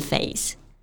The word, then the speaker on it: face: 那这个时候面色肯定是铁青的嘛， blue in the face。我们在读这句话的时候呢，要注意 face 这个单词的发音，中间是发双元音 a 的这个音。我们在发这个音的时候，可以先发 a 的音，再把下巴慢慢抬起来，滑向 a 的这个音，音量是从强到弱。